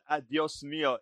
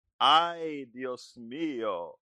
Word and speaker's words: Ay, Dios mío: Anyway, actually, it's not adios mio. It's I, meaning A-Y, Dios mio. That's how you say it, my dear. Ay, Dios mío.